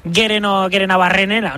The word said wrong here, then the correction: Gerenabarrena: ¿no? – Bueno, pues casi. – Gerenabarrena. – Ahí está.